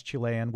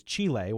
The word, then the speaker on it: Chile: In educated speech, the Spanish CH is similar to the English pronunciation, as in the word chess, but popular dialect turns the CH into something more like an SH. A high-class Chilean would probably pronounce the country's name the country's name is Chile, while someone with less status might say Chile.